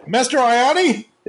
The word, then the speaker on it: Mastriani: Is it Mastriani? – I say Mastriani, but there's a real jumble of vowels in there. I don't hold it against anybody. Like, an O is fine, O-I, that's okay. But when you get to O-I-A, I mean, really, it's anything goes.